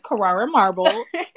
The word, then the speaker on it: Carrara: And the correct pronunciation is Carrara marble.